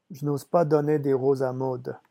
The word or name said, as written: je n'ose